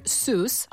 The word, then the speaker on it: Seuss: Drago mi da ste izgovorili doktor Zojs, jer ja ga isto tako izgovaram, ali navodno nije tako, uvijek me ispravljaju. On jest nekog njemačkog podrijetla, ali Amerikanci ga izgovaraju doktor Seuss.